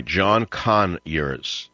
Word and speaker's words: Conyers: That's right, John Conyers, emphasis on the first syllable.